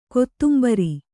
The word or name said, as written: ಕೊತ್ತುಂಬುರಿ